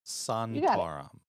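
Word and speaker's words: Sontaran: did Santarum. I pronounce it right? – Santarum.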